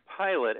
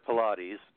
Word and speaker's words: Pilate: Well, actually, you know, it could be a story about really svelte afterlife people, but actually it's more like Pilate, as in the guy who killed Christ, John. So the character is John Pilate, who's got the same pronunciation as Pontius Pilate. – Oh, there you go. Is he a distant relative at all? – Do not feel bad, because everyone says Pilates.